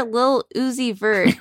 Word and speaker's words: Lil Uzi Vert: Rapper Lil Uzi Vert, did I say that right? – It just sounds funny.